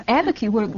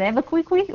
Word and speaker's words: Abiquiú: No, everyone thinks I've misspelled Albuquerque, but it's Abiquiú. – Wildly misspelled. – Yeah, wildly. Or they pronounce it Abiquiqui, or...